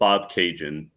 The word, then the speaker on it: Bobcaygeon: And one of their best songs is a song called Bob Cajun, which is about a small town about 2 hours northeast of Toronto. If you want to look it up, it's spelled B-O-B-C-A-Y-G-E-O-N.